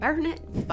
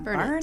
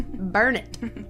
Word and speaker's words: Burnet: And I was like, Burnet, by the way. That's how they say it. Not Burnet. Burnet? It's Burnet. – Like Durnet? – That's what they say. Burnet, Durnet. Exactly.